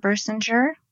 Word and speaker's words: Birsinger: Barbara Birsinger, and my last name is spelled like Bird Singer without the D.